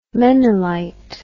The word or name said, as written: menilite